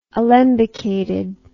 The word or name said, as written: alembicated